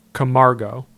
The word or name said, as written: Camargo